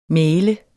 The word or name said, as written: mægler